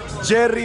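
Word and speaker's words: Yerry: I'm here to tell you how to pronounce the name of our happiest player in the World Cup. His name is Jerry Mina, and he has all the best dance moves for you to see when we beat England this Tuesday.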